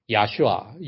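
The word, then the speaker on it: 야슈아 (ישוע): 제가 정기적으로 보는 유튜브가 그 야슈아, 유대인들은 그 히브리 발음을 야슈아라고 그러거든요.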